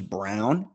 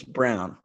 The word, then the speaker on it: Braun: Garrett, this guy's last name is Brown. It's spelled B-R-A-U-N. Think about that for a second. That's Braun. That's Braun. – Yeah, I was. – But it's Brown.